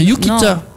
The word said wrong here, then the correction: yukata: Non, c'est non, un yukata.